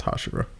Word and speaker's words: Hashira: That's how it's pronounced, Hashira.